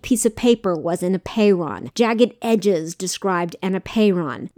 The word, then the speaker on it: apeiron: It's spelled A-P-E-I-R-O-N, apeiron.